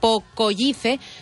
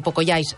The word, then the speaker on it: Pocoyice: Pocoyice. Sí, la Cristina em diu Pocoyais. Sí, el que passa és que jo ho pronuncio tal qual s'escriu, perquè la gent, si no, es farà un embolic.